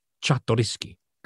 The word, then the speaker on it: Czartoryski: the Czartoryski collection.